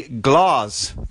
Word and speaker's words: Glas: Johnson Controls partnered with Microsoft to build its Cortana assistant into the Glas thermostat. I'm saying it that way because it's spelled G-L-A-S.